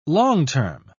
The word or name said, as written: long-term